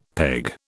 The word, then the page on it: peg: British English